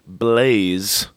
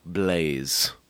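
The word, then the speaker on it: Blāz: what I'm saying is we call the podcast Blaze. Right. – Okay. – But there's no E on the end, and the A has a little line over the top that lets you know it's the long A.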